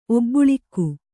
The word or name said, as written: ಒಬ್ಬುಳಿಕ್ಕು